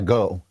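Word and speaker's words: Ngo: Journalist and editor-at-large for the Post-Millennial, Andy, how do you pronounce it? Do you guys know it's Go? It's N-G-O. What is it? Yeah, Go.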